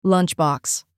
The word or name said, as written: lunchbox